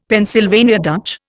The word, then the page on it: Pennsylvania Dutch: Indian